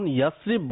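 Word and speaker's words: ইয়াসরিব (يثرب): একে কি ইয়াসরাব বলতে পারবো? – সঠিক উচ্চারণ হলো ইয়াসরিব।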